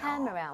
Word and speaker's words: camera: Camera. 오. – Camera. 근데 진짜 무슨 이름절인 것처럼 라, 라 이렇게 돼야 돼요. Camera. 나눠지는 순간 발음이 엄청 어색해집니다.